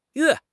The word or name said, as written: yue4